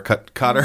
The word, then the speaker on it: Qatar: Am I saying that right? Qatar? – Mm-hmm. Yes. – Qatar, welcome back, Qatar.